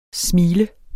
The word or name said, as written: smil